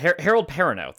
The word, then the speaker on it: Harold Perrineau: Harold Perrineau.